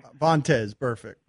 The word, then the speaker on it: Vontaze Burfict: So I was watching your show recently, and you were talking about Burkez Burfict, I think. Did I pronounce his name? – Vontaze Burfict. – Vontaze, yeah.